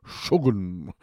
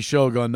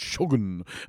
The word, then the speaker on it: Shogun: And I'll tell you what, hey, A new show, 5 weeks in now, but Shogun, or as they pronounce it, Shogun. You know, it used to be Shogun, now it's Shogun.